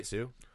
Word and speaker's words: Hsu: Am I saying that right, Sue? Anywho. – I think so.